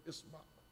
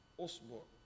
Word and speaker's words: إصبع: Actually, there is 18 ways to pronounce the word isba. The Arab pronounced the word isba in 18 different ways. ثمان وعشر من لغات أتت لنا. There's 18 ways been recorded from the Arab how they pronounce the word isba, usbu, isba, asba.